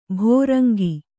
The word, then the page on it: ಭೃಂಗಿ: bhřngi